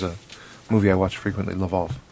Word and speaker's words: Lwów: I believe that that's pronounced Lwów, because I believe that is a Polish town.